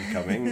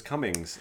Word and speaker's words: Cumming: So, my learnings this week were I don't know very much about the actor Alan Cumming, it turns out off the top No. – of my head. Also, I've been saying his name wrong my entire life. I thought it was Cummings.